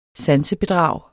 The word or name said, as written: sansebedrag